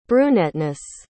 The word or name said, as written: brunetteness